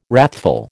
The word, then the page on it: wrathful: American English